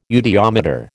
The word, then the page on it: eudiometer: American English